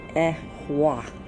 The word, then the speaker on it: Ehwa: And then there's the Iwas. Okay, it's the, that's what it looks like. It says it's the Iwas. But then it's the Ehwa. Ehwa is what it's pronounced.